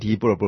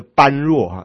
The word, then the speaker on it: 般若: 那么，那可说是啊，约定成熟地啊，把般若这两个字呢，念成字典上所没有的音。所以很多人呢，咱们台湾呢、就念着般若哈，也很多人这样念哈，而成为佛法呢才有的破音字啊。